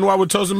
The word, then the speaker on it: Wauwatosa: From the Nicolet Law Studios, this is WXSS and HD1 Wauwatosa, Milwaukee. I don't even know if I said that right. – Sounded right to me. WXSS and HD1 Wauwatosa, Milwaukee.